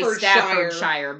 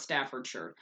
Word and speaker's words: Staffordshire: And it is pronounced I'm saying the actual correct pronunciation of it. If Americans were to look at it, we would want to say Staffordshire, but no, it is pronounced Staffordshire.